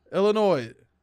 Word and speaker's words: Illinois: Illinois. – Springfield. – You know, I just learned that you don't pronounce the S on that.